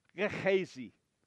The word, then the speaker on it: Gehazi: She's going to encounter Gehazi, which, by the way, I am totally butchering that guy's name. If you've heard it pronounced a different way, it's totally reasonable. If you pronounce it in Hebrew, it's Gehazi.